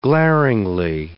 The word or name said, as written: glaringly